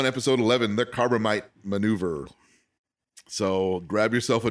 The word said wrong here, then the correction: Corbomite: Corbomite. – Corbomite? Is that how it's pronounced? – Yeah. – Corbomite.